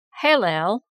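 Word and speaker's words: Hêlēl: This was taken over from the Latin Vulgate, and many scholars prefer to use other words which more correctly translate the Hebrew Hillel, pronounced Hillel, H-A-I-L-A-I-L, or Hillel, H-E-I-L-E-I-L.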